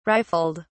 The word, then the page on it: rifled: British English